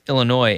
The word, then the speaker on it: Illinois: Ooh, I pronounced the S. Illinois and Rutgers.